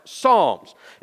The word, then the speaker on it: Psalms: Starts with a P, but it's pronounced Psalms.